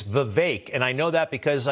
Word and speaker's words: Vivek: And he says, it's Vivek. And a lot of people say it the way you just said it, but it's Vivek, and I got that from him.